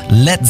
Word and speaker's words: Led: Led Zeppelin. Das A in Led ließ er einfach weg, damit es nicht wie Blei ausgesprochen wird.